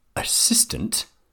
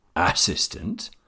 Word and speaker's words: assistant: The stressed syllable in the word assistant is the second one. If I stress the first assistant or last assistant, it is difficult to understand.